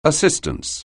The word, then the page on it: assistance: anglais